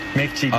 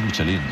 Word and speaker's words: Michelin: Michelin. A ver, en la península ibérica hacemos Michelin.